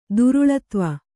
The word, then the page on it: ಧಾರಾಳತ್ವ: Kannada